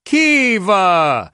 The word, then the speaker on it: Kyiv: The guy who was hosting it seemed to say Kyiv, and then the trio of girls who said basically, hi, welcome to Kyiv or Kyiv, said Kiva.